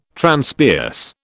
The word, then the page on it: Transpierce: British English